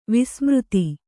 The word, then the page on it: ವಸುಮದ: vasu mada